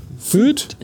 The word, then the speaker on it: fyyd: Feed. – Ich glaube, korrekt ausgesprochen heißt es Feed.